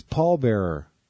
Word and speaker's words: Paul Bearer: You had the emphasis on the wrong syllable there, dude. I didn't know. – Oh, Paul Bearer? – He's Paul Bearer. – Oh, well. – You got to have the emphasis on the Paul.